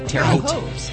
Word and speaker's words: Terre Haute: Terre Haute.